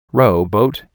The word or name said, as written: rowboat